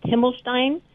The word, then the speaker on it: Himmelstein: And technically, it's pronounced Himmelstein. – Himmelstein? – It's actually pronounced with an H.